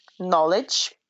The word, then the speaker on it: knowledge: Когда K стоит перед согласным в начале слова, то она не читается. В таких словах, как know, knowledge, knock, knife, Knight, как рыцарь, and knee, которая коленка.